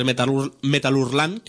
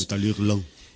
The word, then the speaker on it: Metal Hurlant: Evidentemente, tiene que ver con el magazine francés Metal Hurlant, que creo que era la pronunciación en francés. – Metal Hurlant.